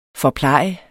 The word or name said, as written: forpleje